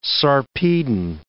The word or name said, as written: Sarpedon